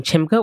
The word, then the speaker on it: Chimbga: You can find me on Instagram at Lorraine Chimga, which is one word, LORRAINE.